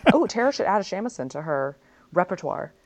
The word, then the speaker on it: shamisen: The Japanese pronunciation is usually shamisen, but sometimes jamisen when used as a suffix. In Western Japanese dialects and several Edo period sources, it is both written and pronounced as samisen.